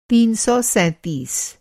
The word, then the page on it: तीन सौ सैंतीस: تین سو سینتیس